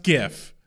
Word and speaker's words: GIF: My only qualm is we do not mispronounce GIF. It's pronounced GIF. – Also, G-I-F. GIF.